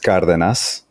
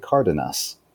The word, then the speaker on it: Cardenas: Like, so I can give you guys like the Spanish pronunciation and the gringo pronunciation that I share with all my friends here in Georgia. It's pronounced Cardenas, but you can just say Felipe Cardenas.